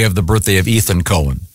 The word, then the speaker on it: Cohen: Leonard Cohen. – Cohen. Yeah, you. – This that's confusing because no, not when it's spelled C-O-H-E-N. – Yeah, it's definitely Cohen.